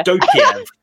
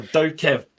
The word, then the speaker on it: Dokev: Dokev or Dokev D O K E V. That's how you spell it.